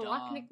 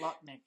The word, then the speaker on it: Loch Ness: Like, oh, the Loch Ness Monster? – The Loch Ness Monster? – I don't know how to say it. – Not Loch Nick. It's not a lockpick.